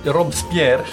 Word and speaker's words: Robespierre: Skräckväldet är ju en period där man ser eh, jag tror han heter Robespierre. – Ja, Robes Robespierre.